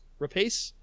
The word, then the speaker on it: Rapace: How do you say her name? – Rapace.